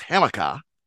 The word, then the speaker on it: Hamika: We've got to go with a Boston accent there. It's Hamika, and it's Rashid, like Rashid Wallace.